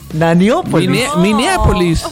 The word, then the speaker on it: Minneapolis: El Consejo Municipal de Ñañópolis decidió de porque dice Ñañópolis. – Minneapolis. – Minneapolis. – Eso. – Maniópolis. – Monópolis. El Consejo Municipal de Minneapolis decidió desmantelar. – Minneapolis. – Lo dije bien.